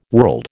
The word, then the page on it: world: American English